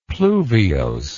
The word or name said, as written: pluviose